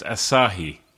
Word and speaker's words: Asahi: It was a long time ago, but I think it's pronounced Asahi. – Yes. Well done.